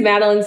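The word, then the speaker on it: Madelein: My name is Madeline Smith. It's spelled weird, so I don't know. It's M-A-D-E-L-E-I-N.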